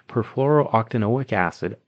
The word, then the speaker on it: perfluorooctanoic acid: In the late 1990s, attorney Robert Billott filed a lawsuit against DuPont alleging chemical waste, specifically perfluorooctanoic acid.